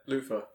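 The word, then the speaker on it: Luther: Luthor. – Luthor. – Oh, I thought that was very obvious. – I was saying it with the O-R rather than the E-R.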